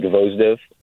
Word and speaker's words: Gvozdev: And Gvozdev, I guess in English would be Gvozdev.